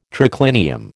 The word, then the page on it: triclinium: American English